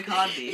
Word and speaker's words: Kotb: I'm positive it's Hoda Kotb. – You're probably right, I just never had it's spelled K-O-T-B. Kotb.